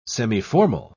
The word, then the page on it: semiformal: British English